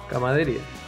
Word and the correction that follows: camaradería: Camadería. De camada. camaradería. De camarada.